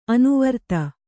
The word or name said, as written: ಅನುವೃತ್ತ